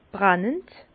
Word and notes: brennend: Bas-Rhin; Herrlisheim

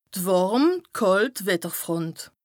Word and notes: d' wàrm / kàlt Wetterfront: Bas-Rhin; Strasbourg

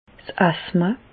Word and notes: 's Asthma: Bas-Rhin; Reichshoffen

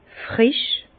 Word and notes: frisch: Bas-Rhin; Herrlisheim

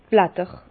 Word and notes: d' Blatter: Bas Rhin; Herrlisheim

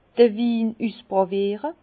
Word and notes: d'r Win üsspràwiere: Bas-Rhin; Herrlisheim

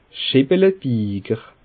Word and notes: Scheppelebiiger: Bas Rhin; Schiltigheim

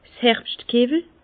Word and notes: 's Herbschtkewwel: Bas Rhin; Herrlisheim

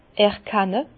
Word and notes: erkenne: Bas-Rhin; Herrlisheim

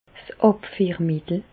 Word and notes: 's Àbfiermittel: Bas-Rhin; Reichshoffen

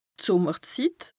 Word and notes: d' Sùmmerzit: Bas Rhin; Strasbourg

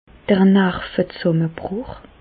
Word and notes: d'r Nervezsàmmebrùch: Alsatian; Bas-Rhin; Reichshoffen

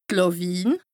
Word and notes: d' Làwiin: Bas-Rhin; Strasbourg

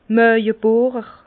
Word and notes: Mààwebohrer: Bas Rhin; Herrlisheim

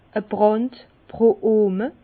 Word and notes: a Brànd pro Ohme: Bas-Rhin; Herrlisheim

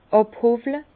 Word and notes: àbhowle: Bas-Rhin; Herrlisheim

